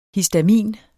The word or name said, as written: HISTAMIN